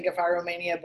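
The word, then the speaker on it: Gephyromania: I had never heard anyone say Gefiromania before. But I don't know. That's just how I say it. – Okay. – That's right. – I typed it in, and the audio pronouncer was like Gefiromania. So right. I was like, okay, soft G, but Gefiro, let's do it.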